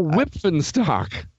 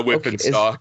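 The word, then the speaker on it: Wipf and Stock: There's a P and there's an – yeah, it's – Oh, Whippenstock. – Yeah, Whippenstock. Okay. – Is that how you do it? – As if the P and F were a PH, like a sound.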